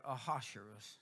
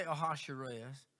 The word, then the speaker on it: Ahasuerus: So in Esther chapter 3, it says in verse number 1 that after these things, the king, I pronounce that Ahasuerus. So my Bible app says Ahasuerus. I assume that computer knows what it's doing. I don't know. So we'll say Ahasuerus.